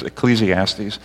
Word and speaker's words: Ecclesiastes: but is Ecclesiastes.